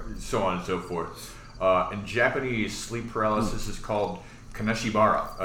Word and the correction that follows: Kanashibari: or Kanashibari. – Credit for pronunciation.